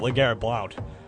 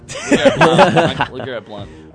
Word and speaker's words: LeGarrette Blount: LeGarrette Blount. – He loves. – Also, one of the few people who pronounces it LeGarrette Blount. – Because that's what it is. – LeGarrette Blount.